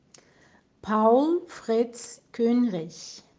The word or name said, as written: Paul Fritz Kuehnrich